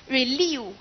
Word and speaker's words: relieve: 记得咱们再看一下 relieve 哈。叫阿姨理我 relieve 是不是？阿姨是不是？看到后边发音叫 leave 理我哈。